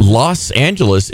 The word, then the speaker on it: Los Angeles: Los Angeles is pronounced or spelled L-O-S, but we pronounce it L-A-S.